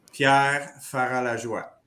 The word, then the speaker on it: Pierre Ferrat-Lajoie: My name is a French name, so I'll do it again just for you. Pierre Ferrat-Lajoie.